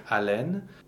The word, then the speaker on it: haleine: So the word for breath, haleine, the H is not pronounced. There is no sound that goes with an H in French. It's a silent consonant.